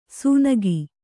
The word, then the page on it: ಸುನಗಿ: sunagi